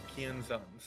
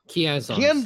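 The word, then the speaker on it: Kienzans: I can't pronounce that. – Barrage of Kianzons. – Kianzons. Thank you.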